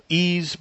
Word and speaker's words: Ys: I'm also really looking forward to the Ease Book 1 and 2 Remake from the TurboGrafx 16 CD-ROM based system. – Is that that Wise game? – Yes. It's spelled YS.